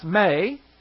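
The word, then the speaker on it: may (μή): The do not in the Greek is one word, M-E, pronounced may, is a negative. And then we have the Greek word hapto, H-A-P-T-O.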